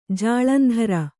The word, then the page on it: ಜಾಲಂದ್ರ: kannada